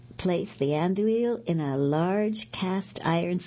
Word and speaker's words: andouille: Oh, and 2 tablespoons of sriracha chili sauce, 2 12-ounce packages of Andalese sausage links. I did not pronounce that right. Andouille, I guess it is sausage links.